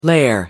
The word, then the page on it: lair: American English